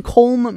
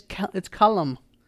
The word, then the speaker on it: Colm: His name isn't Colm Meaney. It's Callum. It's Callum.